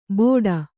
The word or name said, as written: ಬೋಡ